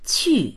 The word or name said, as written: qu4